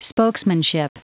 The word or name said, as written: spokesmanship